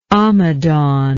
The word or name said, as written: amadan